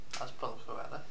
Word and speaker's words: Azpilicueta: Azpilicueta.